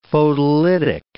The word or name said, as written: photolytic